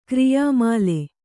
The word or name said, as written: ಕ್ರಿಯಾಮಾಲೆ